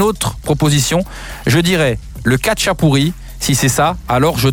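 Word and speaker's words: khachapuri: Exactement, mais je prononcerais plutôt le kacha pourri.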